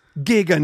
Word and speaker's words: giga: Just giga nut. Just absolutely nut with a hard G, dude.